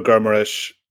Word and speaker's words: Guimarães: He's done a decent job in midfield, but you brought in Bruno Guimarães from Lyon at 40 million. – That's a nice pronunciation. Well done. Yeah. I like that. That was the hint of Portuguese.